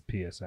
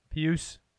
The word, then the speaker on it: Pius: I think it's Pius, actually. – Pius. – Pius. That's what the pronunciation guide says.